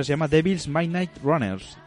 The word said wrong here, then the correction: Dexys Midnight Runners: Dexys Midnight Runners, ¿eh?